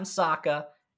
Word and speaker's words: Sokka: And then he goes, that's right, I'm Sokka. It's pronounced with an akka.